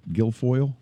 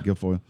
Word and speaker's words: Guilfoyle: Her name is Guilfoyle. Is that how you pronounce it? – Yeah. – Guilfoyle. It's an unusual name.